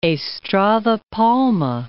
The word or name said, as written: Estrada Palma